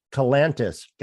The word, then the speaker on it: Kalantzis: It's Kalantzis. Kalantzis. Gotcha. Uh, like Atlantis, right? Okay, with the K.